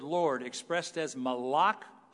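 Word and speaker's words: malach (מלאך): This angel, this malach, as it's pronounced in Hebrew, this malach of the eternal, of Yahweh, possesses God's name.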